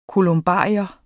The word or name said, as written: kolumbarium